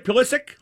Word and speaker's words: Pulisic: Am I pronouncing it right? Pulisic? Okay.